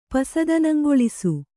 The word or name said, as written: ಪಸದನಂಗೊಳಿಸು